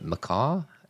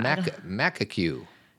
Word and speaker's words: macaque: And it's this monkey that looks like, how do you pronounce it? Macaw? – Yeah, I think that's right. – I feel like macaw's a bird. – Oh, yeah, yeah, yeah. – Macaque.